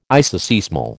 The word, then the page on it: isoseismal: American English